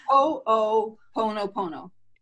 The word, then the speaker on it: Ho'oponopono: It's why, you know, the Ho'oponopono that I can't pronounce. – It's Ho'oponopono.